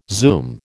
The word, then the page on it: zoom: American English